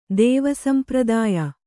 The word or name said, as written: ದೇವಸಂಪ್ರದಾಯ